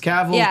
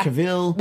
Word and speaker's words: Cavill: But yeah, you know, maybe it's a tomato-tomato thing. It's Cavill, Cavill.